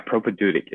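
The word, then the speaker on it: propaedeutic: Yeah, propedeutic.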